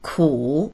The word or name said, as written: ku3